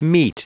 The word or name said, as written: mete